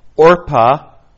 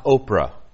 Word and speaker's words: Orpah: I would like to educate you in the correct pronunciation of her sister's name. It was Orpah, not Oprah. Orpah.